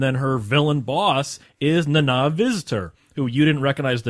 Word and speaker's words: Nana: It's pronounced Nana. I've heard her pronounce it Nana. You got to say it fast, Nana.